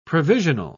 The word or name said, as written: provisional